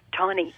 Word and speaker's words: Tawny: Tawny. It just reminds, it does, sounds like Connie, but with a T.